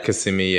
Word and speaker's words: Kissimmee: Yeah, we stayed in Kissimmee. Is that how you say it? – Kissimmee, yeah.